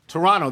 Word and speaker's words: Toronto: By the way, didn't we learn from the movie Argo that it's pronounced Toronto? – Toronto.